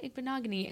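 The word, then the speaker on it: Igbinoghene: So I said, Noah Igbinogany. And then I sounded it out for her.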